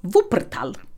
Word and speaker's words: Wuppertal: Hennes dansuttryck möttes inte alltid med öppna armar eh, eller förståelse i den gamla industristaden Wuppertal också perfekt uttal.